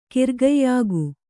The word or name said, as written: ಕಿರ್ಗಯ್ಯಾಗು